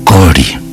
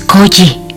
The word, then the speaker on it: Koji: Es Koji. – Ipei, sin perder la sonrisa de los labios torcidos, repitió: Kori. – No, Kori no. Es Koji.